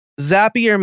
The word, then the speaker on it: Zapier: Zapier makes you happier, is what people say to remember the pronunciation.